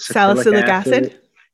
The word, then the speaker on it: salicylic acid: Salicylic acid.